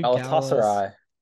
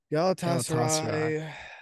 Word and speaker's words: Galatasaray: Galatasaray. – Galatasaray.